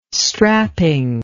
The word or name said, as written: strapping